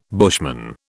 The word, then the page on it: bushman: British English